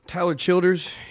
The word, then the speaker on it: Tyler Childers: And in the meantime, we're going to talk to our friend Tyle Childress. Is that, did I say it right? – Tyler Childers.